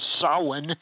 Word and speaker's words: Samhain: which they called Samhain. You'd never know, by the way, how you spell that, how you pronounce it from the way it's spelled.